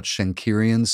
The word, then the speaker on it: Schenkerian: Something about Schenkerian studies, am I saying that right? – I believe so.